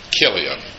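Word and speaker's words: Chilion: At least one translation spells that with a K, not a CH, which gives you a hint of how it's supposed to be pronounced. Kilian.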